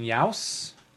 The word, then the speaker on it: Yauss: And then a mopping up over here by Brian Yauss, right? Rhymes with house is how I remember.